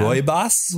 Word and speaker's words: rooibos: I am drinking raspberry rooibos tea. – That's how it's pronounced. Okay. – That is how. – Rooibos? – Rooibos.